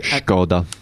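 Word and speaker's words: Škoda: Another interesting car we're looking at, it was at the moment it's only a sketch, but Skoda have revealed a drawing of their Vision C. – Skoda.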